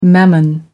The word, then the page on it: mammon: American English